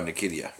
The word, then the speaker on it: Nketiah: Oh, Nketiah.